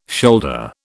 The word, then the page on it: shoulder: British English